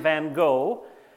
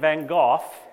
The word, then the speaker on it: Van Gogh: I've learned that North Americans tend to say his name Van Gogh, and the British tend to say his name Van Gogh, and the Dutch tend to say his name Van Gogh. So I'm American, Canadian, so I'm going to say Van Gogh, but I'm sure how he said it was the right way, but I don't know how that was.